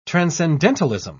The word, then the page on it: transcendentalism: British English